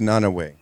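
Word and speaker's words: Nanaue: It's pronounced Nanaway. – Nanaway. There we go. That makes sense.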